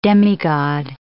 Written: demigod

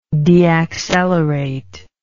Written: deaccelerate

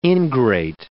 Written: ingrate

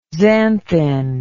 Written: xanthin

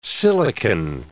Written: silicon

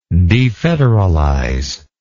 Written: defederalize

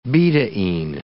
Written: betaine

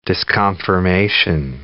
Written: disconfirmation

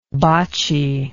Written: boccie

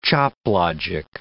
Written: choplogic

